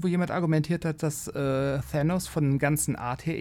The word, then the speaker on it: Thanos: Also, wobei ich habe tatsächlich mal gehört, also optisch ist natürlich Dark, ist natürlich Thanos oder Thanos oder wie man auch immer ihn aussprechen möchte. – Thanos ist cool, wollte ich nur kurz sagen. Thanos ist richtig, Thanos ist super cool, aber Darkseid war zuerst.